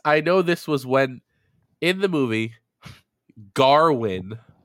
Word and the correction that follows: Gawain: apparently, is his name. – Gowan. – It's spelled Gawain.